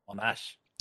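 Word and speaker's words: Monasch: The family name was actually spelt Monash, S-C-H, which pronounced the emphasis on the second syllable. And both John and the family spoke German as their native language. Did not know that. There you go. – So it would sound like Monash.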